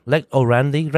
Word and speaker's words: Lex orandi: Lex orandi, lex credendi.